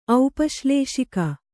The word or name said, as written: ಔಪಶ್ಲೇಷಿಕ